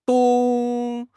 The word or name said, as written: dong1